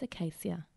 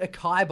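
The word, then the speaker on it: açaí: A-C-A-I. I'm sure I'm not even pronouncing it right. – I think it's acacia. – Acacia? No, there's acai. Only one C.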